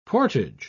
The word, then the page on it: portage: British English